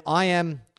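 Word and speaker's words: Iam: And then I am, well, it's spelt like Ian, except it's I-A-M. I'm pronounced I am.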